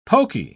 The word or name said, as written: poky